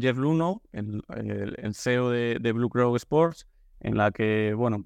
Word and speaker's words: Blue Crow: Y, y bueno, aparece la oportunidad de, vincularte al, al, Club Deportivo Leganés más bien vincularte a, Blue Crow, que lo he dicho mal eh, la primera vez, eh, así que corrijo, haga la redundancia.